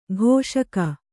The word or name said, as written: ಘೋಷಕ